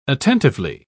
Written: attentively

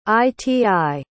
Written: iti

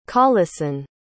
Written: Coleson

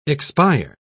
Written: expire

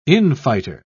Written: infighter

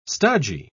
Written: stodgy

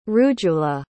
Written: rujula

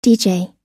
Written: dj

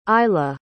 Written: eila

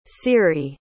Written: ciri

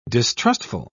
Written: distrustful

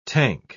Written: tank

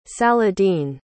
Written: saladin

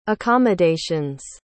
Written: accommodations

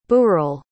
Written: burel

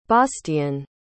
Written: bastian

